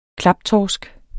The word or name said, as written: klaptorsk